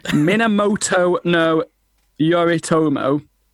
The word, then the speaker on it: Minamoto no Yoritomo: Minamoto no Yoritomo.